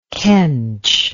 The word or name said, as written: kench